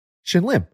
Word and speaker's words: Shin Lim: Yeah, shout out to Lin Shim. – Shim, yep. – What? – Shin Lim. – Oh, Shin Lim. That's what I said. – He said it wrong.